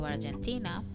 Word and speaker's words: Argentina: So Argentina is very similar pronunciation in English, but the difference is the letter G. When I say it in English, Argentina, Argentina, is unreally pronouncing the G quite strong. Argentina, and in Spanish, is more like the G is like a J.